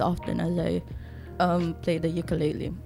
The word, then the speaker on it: ukulele: So your instrument of choice is for the titos who have no knowledge on musical instrument, this is a ukulele. – Yeah. – Is that how you pronounce it, ba? Ukulele.